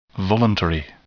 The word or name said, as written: voluntary